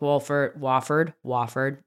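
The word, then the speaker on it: Wolford: Could be playing with Wofford, the L is silent.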